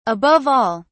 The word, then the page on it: above all: British English